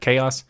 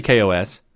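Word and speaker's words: KaOS: Up next in the show is the latest release of 2019.10 for KOS. And if you're looking at the video version, you might look at it and go, isn't that chaos? It's actually KOS. That's how they pronounce it.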